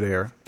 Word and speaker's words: Ayer: Ayer or Ayers? – Ayer. – David Ayer.